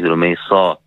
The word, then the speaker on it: Rumaysa: Yeah, the name Rumaysa is not the correct pronunciation. The correct pronunciation is Rumaysa. And so if you're saying it correctly, alhamdulillah, you should say Rumaysa.